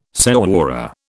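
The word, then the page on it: señora: British English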